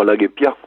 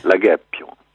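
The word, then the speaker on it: La Guépie: C'est mieux que de dire la Guépia ou la Guépio, parce que en fait, il y a un accent tonique en occitan. C'est comme en italien, en espagnol, en anglais, en toutes les langues. Il y a un accent tonique, on dit donc la Guépio. La Guépio. Pas la Guépio, on n'insiste pas sur la dernière syllabe et sur le haut.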